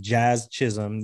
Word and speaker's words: Jazz Chisholm: But, you know, if you only got a few, you got Jazz Chisholm. That's how you say it, Boski. Jazz Chisholm.